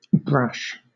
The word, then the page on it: brash: British English